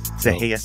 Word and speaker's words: Sereia: Serreia Sentimental, but it's spelled S-E-R-E-I-A and then sentimental.